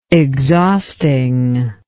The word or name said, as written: exhaustingly